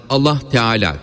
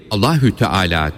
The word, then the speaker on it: Allahü Teala: Bismillahi derken H'yi belli etmek lazımdır. Bunun için her zaman Allah Teala dememeli, Allahü Teala deyip H harfini belli etmeye çalışmalıdır.